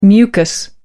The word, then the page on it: mucus: American English